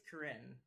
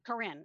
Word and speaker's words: Corinne: Okay, and now this one is, and I hope I say the name correctly again, Kirian? – I think it's Corinne. – Corinne. I'm sorry. I don't know why I want to put a Minnesota extra syllable in there.